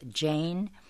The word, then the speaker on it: Jain: Now, this is spelled J-A-I-N. I actually looked it up, as I do for words I usually don't know. The pronunciation is both Jain and Jain, like shine.